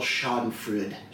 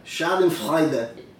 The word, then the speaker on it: Schadenfreude: But I can't help but take a little Schadenfreude. Is it Schadenfreude? – What? – Is that it? Is that how you pronounce it? – Schadenfreude.